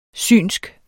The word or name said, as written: Synske